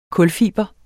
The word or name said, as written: kulfiber